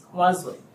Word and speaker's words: Nwazu: Robert Fay, R-O-B-E-R-T-F-E-Y. – All right, and for the Commissioner of Revenue? – Chidirim Wazwe. – And can you spell your name for the record, please? – H-I-D-I-R-I-M, last name N-W-A-Z-U. – All right, and how do you pronounce that again? – Wazwe.